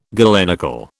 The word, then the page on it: galenical: British English